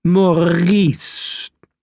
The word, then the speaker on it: Maurice: At the front was Maurice, the trapeze artist, whose name is pronounced Maurice, as if you are gargling an espresso of pond water.